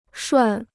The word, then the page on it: 涮: shuàn